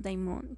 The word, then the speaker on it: daimon: En religión, ocultismo y folclore, un demonio, un daimon, que se pronuncia Daimon es un ser sobrenatural, descrito como algo que no es humano y que usualmente resulta malévolo.